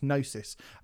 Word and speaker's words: Gnosis: It's Gnosis, but I think it's pronounced Gnosis.